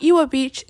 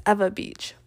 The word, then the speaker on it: Ewa Beach: It is not Hawaii, it's Hawaii, because the W's here are pronounced as V's. Like, it's not Iwa Beach, it's Ewa Beach.